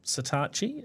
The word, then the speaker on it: Satechi: Now, Satachi is a gadget brand, and I don't know why you pronounce it that way, but that's what Google tells me. Satechi. I would have thought Sateki, but anyway, Satachi is what they tell us.